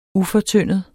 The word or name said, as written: ufortyndet